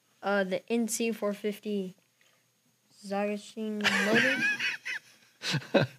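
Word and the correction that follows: Zongshen: Hey, you pronounce it as good as I could. Say it again. – Zagashin? – Zongshin motor.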